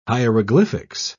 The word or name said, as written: hieroglyphics